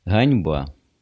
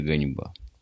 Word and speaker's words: ганьба: Ганьба. Не то, что я большой любитель Пушкина, но это ганьба. Только не ганьба, а ганьба. Г.